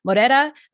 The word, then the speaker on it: Moreira: And today I have with me Israel Moreira, who is the co-CEO of Doughp, which is spelled D-O-U-G-H-P.